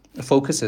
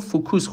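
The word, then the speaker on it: focus: شما فرض کنید یه فایل صوتی گوش میدید، طرف تو جملهش از فوکس استفاده میکنه، شما این رو فوکوس خوندید، عملا چیزی به اسم فوکس رو میشنوید،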